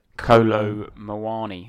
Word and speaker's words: Kolo Muani: Kolo Muani. I want to say his name was. – Good. I'm going to go with that. Yeah. – Is that pronounced correctly? Yeah.